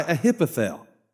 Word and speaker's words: Ahithophel: Meanwhile, Absalom and all the army of Israel arrived at Jerusalem, accompanied by Ahithophel.